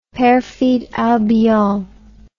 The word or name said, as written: perfide albion